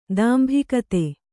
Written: ದಾಂಭಿಕತೆ

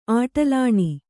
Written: ಆಟಲಾಣಿ

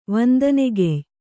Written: ವಂದನೆಗೆಯ್

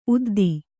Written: ಉದ್ದು

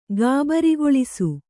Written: ಗಾಬರಿಗೊಳಿಸು

